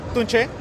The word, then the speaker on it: Tunche: Well, Tunchi, am I saying it right? – Since the name is in Spanish, it's pronounced Tunche, but we don't mind.